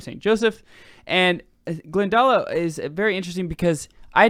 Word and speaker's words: Glendalough: And from Kildare, we're going to go to, on day eight, Glendalough. How do you say that? – Yeah. – Glendalo? – Yeah.